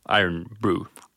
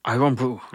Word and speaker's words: Irn-Bru: Det stavas alltså I-R-N-B-R-U. Och så med skotskt uttal så ska det väl bli Iron Brew. – Iron Brew.